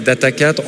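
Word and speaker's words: Data4: Donc moi je prononce en fait Data 4 en fait avec le, puisqu'on est très local, on dit Data Quattro, Data 4, Data Sphere.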